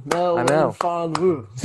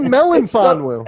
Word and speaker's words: Melifonwu: Melifonwu. – See, you just did it again. But you just called him Melifonwu. It's Melifonwu. There's no N.